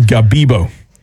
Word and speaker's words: Gabibbo: Gabibbo.